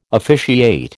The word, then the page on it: officiate: American English